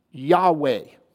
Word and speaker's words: Yahweh: Y-H-W-H. And most of all, it's mostly pronounced as Yahweh. There would be some who would say Yahweh. Yahweh is often how we say it because we're so used to that W being pronounced Hua. Yahweh.